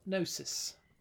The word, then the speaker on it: gnosis: This is unverified personal gnosis. – Yeah. – With a G that you don't pronounce on gnosis.